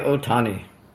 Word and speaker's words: Ohtani: Shohei Ohtani.